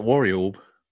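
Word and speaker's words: Warriorb: I know it's good at Worry Orb. It's like Warrior, but with an orb at the end, isn't it? – Worry Orb.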